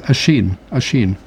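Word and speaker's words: Oisín: I didn't know how to pronounce Oisin, which is O-I-S with I-N with an accent over it. So it's Oisin. – Oh. – And Finn, of course, it looks like Fionn, F-I-O-N-N, but it's Finn.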